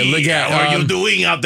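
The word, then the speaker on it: Legette: Did I pronounce the name wrong? – Leggett. – Okay, Xavier. Close enough. I went with the French version.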